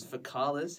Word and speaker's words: faecalis: Whereas Ficalis has the hard C, and then common also has the hard C.